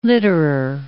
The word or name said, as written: litterer